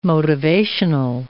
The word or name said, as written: motivational